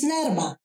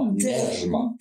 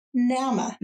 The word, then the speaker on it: Nerma: My name is Nerma. – Nerma, yes. – You see, that's a bit of the roll on the R. Nerma. – The hard R. – Yeah. – That the Danes cannot pronounce.